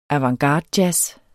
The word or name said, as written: avantgardejazz